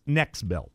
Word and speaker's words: NexBelt: Next Belt is spelled N-E-X-B-E-L-T. There's no T after the T. It's not Next Belt. It's Next Belt.